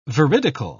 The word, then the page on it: veridical: British English